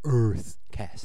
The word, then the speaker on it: UrtheCast: Yeah, it's URTHE... CAST, so EarthCast.